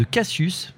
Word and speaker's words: Kacius: vous êtes le patron fondateur de Cassius, ça s'écrit K-A-C-I-U-S, euh, conseiller en gestion de patrimoine.